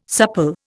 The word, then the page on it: supple: Indian